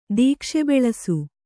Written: ದೀಕ್ಷೆಬೆಳಸು

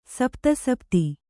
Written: ಸಪ್ತಸಪ್ತಿ

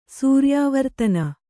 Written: ಸೂರ್ಯಾವರ್ತನ

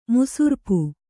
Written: ಮುಸುರ್ಪು

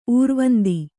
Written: ಊರ್ವಂದಿ

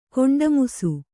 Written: ಕೊಂಡಮುಸು

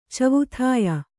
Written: ಚವುಥಾಯ

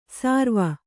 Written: ಸಾರ್ವ

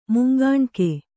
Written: ಮುಂಗಾಣ್ಕೆ

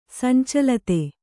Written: ಸಂಚಲಿತೆ

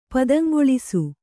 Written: ಪುದುಂಗೊಳಿಸು

